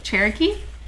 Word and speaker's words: Cherokee: In 1864, a new plant was built on Churro-kee Street and Cardinalets Avenue. – Holy moly. Also, Cherokee? – Did I say that wrong? – You said Cherokee. I thought you were going to say churro.